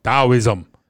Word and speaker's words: Taoism: Taoism. It's like the T is silent, sort of silent. Taoism.